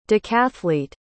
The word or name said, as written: decathlete